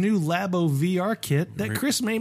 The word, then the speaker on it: Labo: Labo.